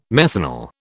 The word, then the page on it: methanal: British English